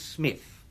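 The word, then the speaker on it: Psmith: My name, perhaps I ought to reveal to you, is Smith. The P is silent.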